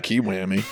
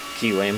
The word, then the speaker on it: Kiwami: Oh, I, I, pronounce it Kiwami. Kiwami. Yeah.